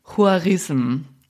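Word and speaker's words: Khwarazm: Also mein Kurs hat ergeben, es heißt ungefähr Huarism. Und das ist interessant, Huarism.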